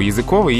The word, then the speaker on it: языковый: Есть два слова – языковый и языковой. Языковый – это прилагательный от язык значение орган полости рта а также такой орган животных, как кушание. Вот, например, языковая колбаса есть. Угу. – А она делается из языка, из языка в этом значении, и она языковая. Ударение на «о».